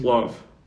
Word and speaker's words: Clough: Clough. It's spelled like Cliff, but with a U. – Clough.